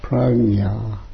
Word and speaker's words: prajna: Wisdom, the word for wisdom is pragya, P-R-A-J-N-A. So people say prajna. And we have the Prajna Paramita Sutra. But it actually pronounced pragna, something like that. I can never pronounce it right, but I had an old Indian man come once and said, I really like what you're doing, but it's called pragna.